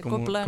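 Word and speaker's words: cobla: Copla con B, no con P. – Exacto, exacto. O sea, se pronuncia cop, bueno, sí, Copla, en catalán.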